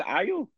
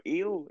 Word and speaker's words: Ayo: sure it's Ayo or Ayo. It's AYO, who's second command of the Dora. – Oh, it's AYO, so it's, I think it's, I presume it'd be nice to pronounce IO.